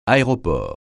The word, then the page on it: aéroport: francais